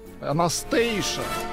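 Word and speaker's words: Anastacia: Э, певица Анастасия, э, фамилия не указывается, пожаловалась, что ей отказали в пенсии. – Анастейша, а не Анастасия.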